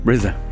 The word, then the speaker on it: RZA: But the thing is, the score was written by RZA. Am I saying that right? From the Wu-Tang Clan. RZA? Come on, hit me. – RZA.